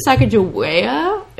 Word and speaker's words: Sacagawea: They actually named it Sacagawea. It's Sacagawea. The pronunciation is very, very important to the river.